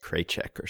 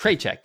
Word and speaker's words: Krawczyk: Do you know how to pronounce K R A W C Z Y K? I'd say like Kraychek or something. Kraychek, yeah, I think you're probably right.